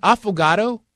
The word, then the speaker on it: affogato: So an affogato is what sounds like – avocado, but it's Italian, and it's spelled A-F-F-O-G-A-T-O.